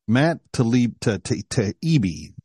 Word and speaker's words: Taibbi: speaking of shocking, Matt Taibbi is how you say his name.